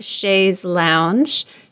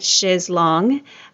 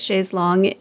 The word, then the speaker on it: chaise longue: I thought it was pronounced chaise lounge. She pronounces it chaise longue. And I did a little bit of research and realized that both are accepted, but chaise longue is the French pronunciation of a long chair.